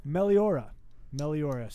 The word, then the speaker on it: Meliora: Meliora. So Ghost put out an album, Meliora, in 2015.